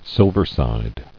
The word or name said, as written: Silversides